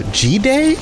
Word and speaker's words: Jide: So this is G-Day, I think is how you pronounce his name. J-I-D-E. I called him Jide the first time I spoke to him, but I think that's absolutely incorrect.